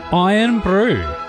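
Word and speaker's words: Irn-Bru: Iron Brew. – I think that's the way to go. That's how they say it in Scotland.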